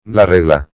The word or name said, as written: la regla